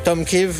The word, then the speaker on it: Tomkiw: Tomkiw, je ne sais pas comment le prononcer, T-O-M-K-I-W-Q. Tomkiw, ah voilà, Tomkiw.